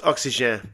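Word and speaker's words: Oxygène: It's pronounced Oxygen, by the way. And I know I started this off on this note because I said Oxygen. When, anyway, Oxygen. It's French.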